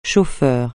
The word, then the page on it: chauffeur: français